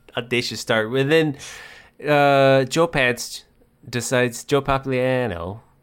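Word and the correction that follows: Pantoliano: Pantaliano is in this movie.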